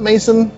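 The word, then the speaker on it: Masson: It was an original picture novel, as you alluded to earlier, and this tells the story of Rust's Mason? Did you pronounce it Mason? It's M-A-S-S-O-N.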